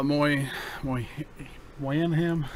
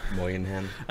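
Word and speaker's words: Bobby Moynihan: Uh, let's move on to uh, uh probably the cast member wise, the one we'll probably recognize is Bobby uh, Moy Moy Moynihan. – Moynihan.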